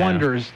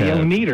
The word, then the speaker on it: Oneders: The Wonders, spelled O-N-E-D-E-R-S. – And someone in the movie goes, pronounces it... – The Oneaters. – Phonetically, the Oneaters.